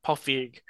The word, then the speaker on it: Feig: It's not Feige, like Kevin Feige. – Paul it's... Feig. Yeah. – Paul Feig.